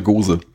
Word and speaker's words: Gose: Das ist eine Gose. – Eine Gose, ist das, spricht man das auf Englisch auch Gose? Ich hätte jetzt Goose gesagt, ehrlich gesagt.